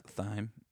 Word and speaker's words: thyme: It was French style lentils with garlic and thyme. – It's pronounced thyme. – Thyme. My bad.